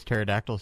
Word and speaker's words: pterodactyl: Why does pterodactyl start with a P? – Does it really? – I love words that start with a P. I always pronounce the P just for fun.